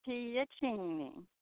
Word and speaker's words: Piacine: Well, it's pronounced Piacine.